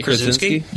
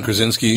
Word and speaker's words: Krasinski: Krasinski. – Yes, sorry about that. – Oh, John Krasinski.